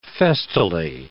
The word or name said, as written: festally